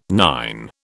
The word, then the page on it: nine: British English